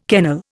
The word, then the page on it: kennel: Indian